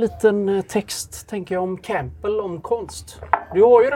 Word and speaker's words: Campbell: Campbell och... – Campbell. – Och, ska inte uttala P här.